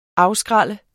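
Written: AFSKRÆLLER

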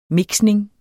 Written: mixning

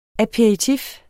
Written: aperitif

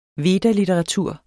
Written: veda

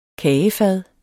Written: Kagefad

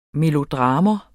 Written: melodrama

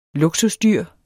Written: luksusdyr

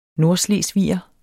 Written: nordslesviger

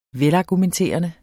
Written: velargumenterende

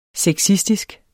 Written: sexistisk

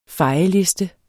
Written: fejeliste

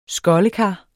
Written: skoldekar

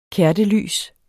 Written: kertelys

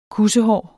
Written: kussehår

